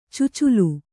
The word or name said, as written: ಚೊಚ್ಚಿಲು